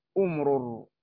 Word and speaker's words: أمرر: نعم فذكر الناظم أي بن مالك رحمه الله منها 28 28 أولها أمرر، هكذا نقول أ تبعيدا دائما الهمزة همزة الوصل في أول كلام تنطق فيقال أمرر، وتضم تضم إذا كان الثالث مضموما، وتكسر إذا كان الثالث مفتوحا أو مكسورا.